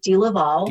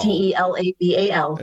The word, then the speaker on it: DeLaval: It's pronounced many different ways, but I pronounce it DeLaval. – DeLaval. – DeLaval.